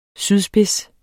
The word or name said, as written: sydspids